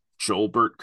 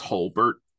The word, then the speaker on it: Jolbert: I do want to mention one of my favorite names of all time, Jolbert Cabrera, is their manager. And I realize I'm pretty sure it's pronounced Holbert. But Holbert is just as funny. – I thought it was Jolbert when I was a kid, and I'm not changing it.